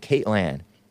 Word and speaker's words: Kaitlan: Trump calls CNN's Caitlin Collins. That's how you pronounce it. K-A-I-T-L-A-N. Caitlin.